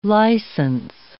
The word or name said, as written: licence